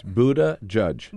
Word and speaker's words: Buttigieg: Buttigieg? Does that sound right? – That's pretty good. We have it to two words. Buttigieg.